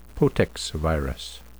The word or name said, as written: potexviruses